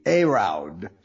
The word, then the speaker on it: Heraud: His last name is spelled H-E-R-A-U-D, Herraud.